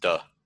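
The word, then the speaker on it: Te: When you embrace the Li in yourself, you're able to work in virtue, and virtue is T-E, how do you pronounce that? – You pronounce it duh. – Duh, okay, yeah, yeah.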